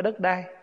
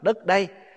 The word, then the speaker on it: đất đây: Thật ra cái chữ đất đai đó, nói cho đúng là chữ đất đây nè, đất ở đây nè, chứ không phải đất đai. Mình nói riết nó thành trại thành cái chữ đất đai, chứ đúng ra là mình phải đọc là đất đây, tức là đất này chúng ta đang ở.